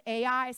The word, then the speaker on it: Ai: Some people pronounce it Ai. Some people just pronounce it I, but it's literally spelled Ai.